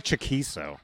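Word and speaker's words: Chaquico: So how do you pronounce his name? – It's Craig Chiquito.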